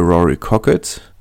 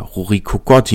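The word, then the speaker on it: Rory Kockott: Eine Alternative, die noch genannt wurde, war eine Beförderung für Rory Cockett. oder Rory Cocotte, je nachdem, wie man es aussprechen möchte,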